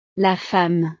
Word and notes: la femme: frances

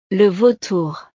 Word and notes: le vautour: frances

